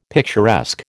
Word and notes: picturesque: American English

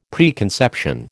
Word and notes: preconception: American English

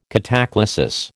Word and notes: cataclasis: American English

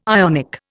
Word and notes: Ionic: Indian

